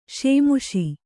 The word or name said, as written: ಶೇಮುಷಿ